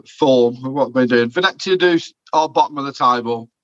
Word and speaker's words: Venezia: Yeah, and it's Roma against Venezia. Excuse me if I'm pronouncing that wrong. – No, both of them are fine. As long as you don't change the pronunciation throughout it, that's my thing.